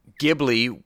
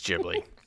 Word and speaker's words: Ghibli: Ghibli, with hard G, was the name of the Italian warplane that represented Hayao Miyazaki's love for planes and for the country of Italy. While the plane was pronounced with that hard G, Miyazaki changed the pronunciation, either by accident or on purpose, to fit the studio. His pronunciation is more like gibberish, with a Japanese accent.